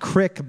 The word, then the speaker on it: creek: It's actually pronounced Crick back then.